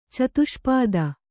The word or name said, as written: ಚತುಷ್ಪಥ